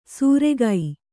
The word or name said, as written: ಸೂರೆಗೈ